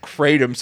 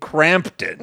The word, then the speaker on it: Kratom: No, it's just you don't know how to pronounce Kratom, so you've been pronouncing it Crampton.